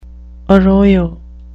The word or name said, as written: arroyo